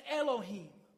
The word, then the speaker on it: Elohim: That word us in the Hebrew is a word that's pronounced in English as Elohim.